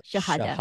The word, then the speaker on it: Shahada: that the, I don't know how to pronounce this, Shahada. – Shahada? – Yeah.